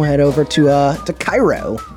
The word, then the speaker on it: Cairo: They pronounce Cairo. Cairo. That's where the Cairo corn syrup comes from, Cairo, Georgia.